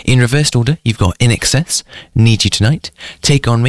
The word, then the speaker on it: INXS: So In Excess, it's INXS, which is supposed to be pronounced In Excess.